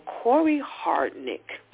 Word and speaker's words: Hardrick: Now, that's starring Megan Good and my boy Corey Hart. I got to make sure I say his name right. I never seem to say his name properly. But Corey Hardrick, he is the one who was married to one of the Maori girls, and they just recently got divorced.